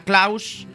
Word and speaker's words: Klaus: HBO el 12 de octubre nos estrena Klaus. Klaus, Klaus, se escribe con A, Klaus. Klaus, como Santa, pero sin el Santa.